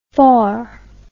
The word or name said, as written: faur